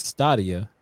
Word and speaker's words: Stadia: Google launched the game streaming service Stadia.